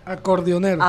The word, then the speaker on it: acordeonero: un acordonero. Lo dije bien. Acordeonero. Acordeonero, sí.